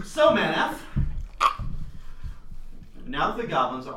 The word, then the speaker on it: Manath: Math? – Manath. – Spell it. – M-A-N-A-T-H. – I'm just gonna spell it how I'm gonna pronounce it. – Manath. – Yeah. – Mayonnaise. – Man and ath. Yeah, Manath. – Okay, so Sebastian, what's your character name? – Crux, K-R-U-X.